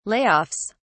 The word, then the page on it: Layoffs: British English